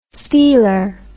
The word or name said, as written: stealer